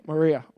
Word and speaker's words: Moriah: Take your son, your only son, whom you love, Isaac, to the region of Moriah. Am I saying that right? Yeah, Moriah.